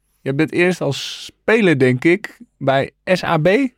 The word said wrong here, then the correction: SAP: Zeg ik dat goed? SAP. SAP. SAP in Breda, ja.